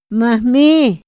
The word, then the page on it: ma hmi: Otomí